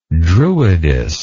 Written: druidess

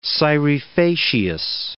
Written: scire facias

